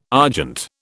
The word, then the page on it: argent: British English